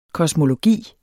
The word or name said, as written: kosmologi